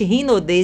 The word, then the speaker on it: Hinode: Gente, Rinode se escreve com H, pronuncia R, mas é Hinode.